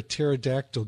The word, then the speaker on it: pterodactyl: Hey, Nita, why can't you hear a pterodactyl going to the bathroom? – I don't know. – Because the P is silent.